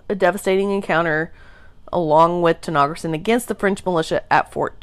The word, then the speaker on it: Tanaghrisson: And Tenogerson, which how it's spelled is T-A-N-A-G-H-R-I-S-S-O-N,